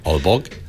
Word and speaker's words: Aalborg: I'm going to pronounce this wrong. At Aalborg University in Denmark. – Aalborg. – Oh, thank you.